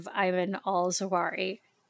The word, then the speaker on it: Ayman al-Zawahiri: And of course, one of the really big events that really fits into the dark Brandon persona beautifully is the assassination of Ayman al-Zawahiri. Am I saying that correctly? – Yes.